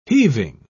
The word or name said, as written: heaving